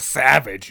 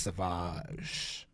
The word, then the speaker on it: Sauvage: Because you look at it and it says, like, savage, and you're like, yeah, I'm a savage. It's actually pronounced Sauvage.